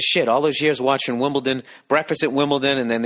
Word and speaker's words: Wimbledon: For years I've been saying Wimbledon. Yet another word that I've been mispronouncing. T-O-N, Wimbledon. But it's Wimbledon, D-O-N.